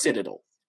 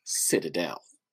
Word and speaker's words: Citadel: First pronunciation, according to Merriam-Webster, is Citadel. – All right. – Second, Citadel.